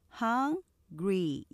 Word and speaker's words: hungry: hungry 이렇게 삼음절로 하실 수 있지만 hungry 이음절로 하면서 앞에 액센트 강조해서 hungry 그 다음 옆에 문장 보시면 lunch 이음절이 아니라 lunch 이렇게 발음하시면 돼요.